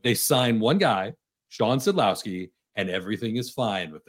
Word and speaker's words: Szydlowski: There's big news with the Komets in terms of signing Shawn Slizdowski, bringing him back. What kind of impact is that going to have on them moving forward? – Boy, we need to work on that pronunciation. Szydlowski. Szydlowski. Shawn That's a hard one.